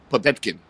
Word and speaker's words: Povetkin: Povetkin.